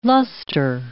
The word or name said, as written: luster